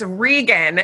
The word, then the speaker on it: Reagan: And I had to ask, hopefully I don't butcher it, but it's Reagan. And we are going to be diving. No, it's Reagan.